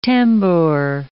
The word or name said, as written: tambour